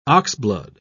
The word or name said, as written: oxblood